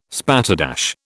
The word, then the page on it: spatterdash: British English